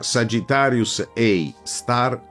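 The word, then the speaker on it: Sagittarius A*: Quello posto al centro della Via Lattea, denominato Sagittarius A asterisco, che si pronuncia Sagittarius A star,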